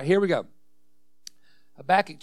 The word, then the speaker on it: Habakkuk: I used to call it Habakkuk. And actually in Hebrew, that's probably a more pronunciation.